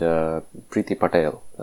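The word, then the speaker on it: Priti Patel: And Mr. Langdon was talking about a woman named Preeti Patel.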